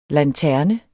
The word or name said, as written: lanterne